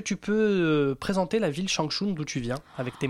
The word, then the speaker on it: Changchun: C'est comme ça qu'on prononce Changchun Changchun.